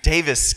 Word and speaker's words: Davis: Is am I pronouncing that right? Okay, I've been saying it Davies because there's many S's in. Davis County Library for having me.